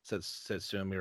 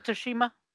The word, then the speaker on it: Tsushima: The Ghost of Tsushima or whatever it's called. – Ghost of Tsushima.